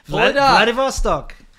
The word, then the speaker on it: Vladivostok: Vladivostok. – Vladivostok. – I agree that's how you'd say it in Russian, all right? – Do you say champagne or champagne?